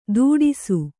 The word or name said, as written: ತುಟಿಸು